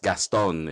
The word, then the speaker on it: Gaston: Oh, Though here in the Philippines, it's phonetically, it's just Gaston.